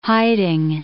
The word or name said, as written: hiding